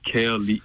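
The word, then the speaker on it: Kalie: It's pronounced Kaali. – K K-A-L-I-E.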